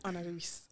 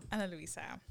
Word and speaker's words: Ana Luisa: Mm, I'm gonna say it correctly today. Ana Luisa. – Ana fuck. Luisa. Ana Luisa.